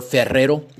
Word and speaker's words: Ferrero: I had Ferrero Rocher chocolate, or Ferrero, if you want to say it properly.